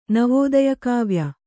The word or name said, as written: ನವೋದಯಕಾವ್ಯ